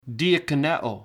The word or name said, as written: diakoneo